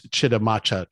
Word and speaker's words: Chitimacha: He works primarily with the, uh, okay, this one I need some help pronouncing, but I think it's Chitimacha tribe. Is that right? Yep, exactly. yep.